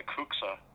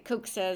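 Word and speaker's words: Cuxa: And the one that most people think of instantly is, the pronunciation's open to some discussion, but I call it the Kuxa Cloister Garden. It's C-U-X-A, and it's completely surrounded by the museum.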